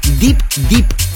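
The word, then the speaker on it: Deep Dip: esto se llama Deep Deep, pero atención porque el primer Deep es D-E-E-P, tal como se escribe, y el segundo es D-I-P, Deep Deep.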